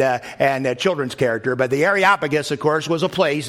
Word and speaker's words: Areopagus: Areopagus, how do you say it? Well, you say it just like Snuffleupagus. If you ever watch Sesame Street, you can say this name, Areopagus. It's just like that.